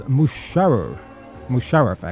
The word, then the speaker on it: Musharraf: Pervez Musharraf, actually, is the way to say his name. Musharraf, or as they say in Pakistan, Mubushraf. Yes, it's spelled M-U-S-H.